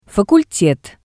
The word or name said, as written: facultet